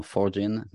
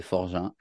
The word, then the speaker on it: Forgin: Je trouve que mon nom de famille est compliqué à prononcer. Moi, parfois, ça me perturbe aussi. Mais euh, c'est un nom de famille euh, très français. Et quand les gens me demandent, c'est comment est-ce qu'on prononce Et je plaisante en disant Forgin, mais c'est Forgin.